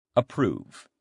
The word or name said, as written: approve